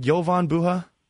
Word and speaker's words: Jovan Buha: Do I have it right, Jovan Buha? – Yeah.